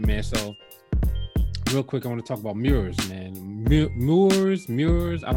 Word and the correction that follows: Murs: You know Moors, Mures, MURS. – Mers? – Mers. Oh, Mers.